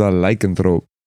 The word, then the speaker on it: lycanthrope: Well, if it's part of your group is a lycanthrope when they just specifically said that they hate lycanthropes. – Oh, they assume sir, you put the emphasis on the wrong syllable. – Lycanthrope? – Lycanthrope?